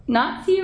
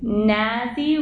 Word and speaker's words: Nazi: Just so you know, Nazi rhymes with potsy, Nazi rhymes with snazzy, likewise the plurals.